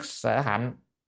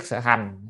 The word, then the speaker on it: nhất thiết sở hành: Bồ Tát nhất thiết sở hạnh, hay là nhất thiết sở hành, đọc hành hành cũng được, nhưng mà đây chúng ta đọc là nhất thiết sở hành này đúng dễ nên nó suông hơn.